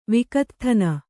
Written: ವಿಕತ್ಥನ

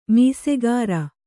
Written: ಮೀಸೆಗಾರ

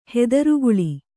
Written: ಹೆದರುಗುಳಿ

